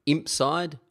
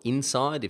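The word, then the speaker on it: Inpsyde: The company behind MarketPress is called Impside, or Inside if the P is silent, which Caspar and I debate in the interview.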